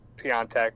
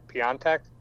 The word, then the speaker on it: Piątek: But there is certainly a pattern with our transfer rumors, and that all of these guys, besides Piątek, is that how you say it? Piątek? – I think you're adding an N. – No, I think actually the Polish pronunciation does have an N in it. That's Jesus Christ. – I'm trusting James Horncastle on this. I've heard him pronounce, he loves Piątek, and I've heard him pronounce it on multiple different podcasts, that way.